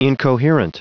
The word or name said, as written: incoherent